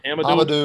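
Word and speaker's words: Hamidou: Hamadou.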